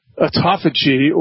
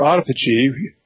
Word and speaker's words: autophagy: Yeah, so one of the primary benefits is we call autophagy or autophagy, spelled the same way.